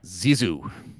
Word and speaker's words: Sisu: Is it Sisu, Steve? Is that how you say it? – Zizu. – Zizu is next.